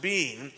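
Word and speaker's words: being (בין): B Y N, pronounced being, and that this word occurs 171 times in the Old Testament.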